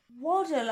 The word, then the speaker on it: Waddell: As in waddle like a duck, Wallace. W-A-D-D-E-L-L, Waddle. – Waddle. – There is no other way I can pronounce it. I'm not giving it the Waddell treatment. It's Waddle.